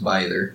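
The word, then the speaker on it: Bither: I've heard her name pronounced probably four different ways, but I think the general consensus on how it is pronounced is Byther.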